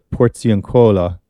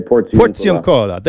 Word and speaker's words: Porziuncola: But let's start with tomorrow, because tomorrow I'm not going to say this properly, but at the Porziuncola, lying on the bare ground. Close. Was that close? Okay. Help me out here. – We would say Porziuncola.